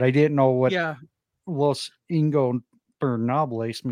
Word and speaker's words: Ingobernables: Ingobernables.